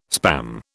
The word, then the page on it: Spam: British English